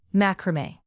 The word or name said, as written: macrame